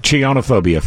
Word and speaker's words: Chionophobia: Chinophobia, fear of snow. – Oh, I thought you were going to say chins. – Oh, I'm sorry. I pronounced it wrong. Chionophobia.